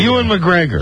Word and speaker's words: Ewan McGregor: Stars Ian McGregor. Am I pronouncing that right? – Ewan McGregor. – Ewan McGregor.